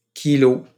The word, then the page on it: kilo: French